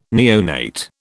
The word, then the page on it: neonate: British English